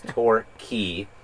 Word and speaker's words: Torquay: Our friend Matt in Melbourne had pointed out the correct pronunciation of a town, which was by Bell's Beach, called, it's actually Torquay. It is not Torquay, it is not Torquay, it is Torquay.